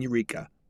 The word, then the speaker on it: Yreka: After staying the night in Eureka, which is spelled Y-R-E-K-A, which is the county seat with about 8,000 residents, 30 miles to the north, they strolled the town.